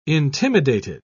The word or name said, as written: intimidated